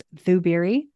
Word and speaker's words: Zubiri: It starts with a Z, pronounced Zubiri.